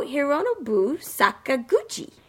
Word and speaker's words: Hironobu Sakaguchi: No, Hironobu Sakaguchi.